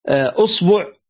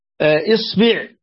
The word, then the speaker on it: إصبع: طبعا الأصبع فيها عشر لغات, هذه فائدة, بفتح وضم وكسر الهمزة وفتح وضم وكسر الباء. يعني أصبع أصبع إصبع, واللغة العاشرة أسبوع على وزن أسبوع. فإذا ما كيف ما تلفظت يا صحيح.